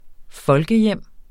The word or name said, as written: folkehjem